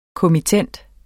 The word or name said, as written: kommittent